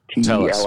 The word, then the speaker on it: Tellis: Adam says the SDG Telvis. – Rhymes. Tellis. T-E-L-L-I-S. Oh, I was having it rhyme with Elvis.